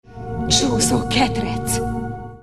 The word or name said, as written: csuszoketrec